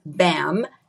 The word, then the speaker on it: BHAM: The name of the business is Beverly Hills Aesthetic Medics, and it's BHAM, BAM. The H is silent.